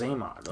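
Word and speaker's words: Xamot: Oh, it's Zaymot.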